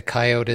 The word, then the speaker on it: coyote: So the other way of pronouncing it, coyote, is more derived off of Native American enunciation of that animal's name.